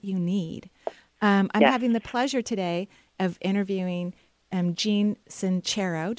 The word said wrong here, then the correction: Jen: Did I say that correctly, Jean? – Definitely Jen. Jennifer. – Jennifer, yes.